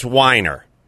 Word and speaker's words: Weiner: it's Weiner. It's always yeah. been Weiner. I was always called, it's pronounced Weiner. It's a German name. It is not Weiner. It's Weiner.